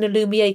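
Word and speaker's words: Alumier: and it's a Lumiere, and it's the Moisture Matte Mineral SPF. Am I pronouncing a Lumiere correctly? I don't know if either of you can. – Yep, you absolutely are.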